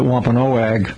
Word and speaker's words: Wampanoag: Yeah, we use the word Wampanoag today. Some people pronounce it Wampanoag.